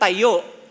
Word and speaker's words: tayo: Pag sinabi mong tayo is to stand. Tama? Or pag sinabi mong itayo is to build. Pero pag binago mo yung pronunciation, tayo, it means to be together.